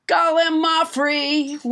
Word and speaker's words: gallimaufry: Let's not forget Gallimaufry, which I pronounced wrong until nobody, James Hyder, corrected me.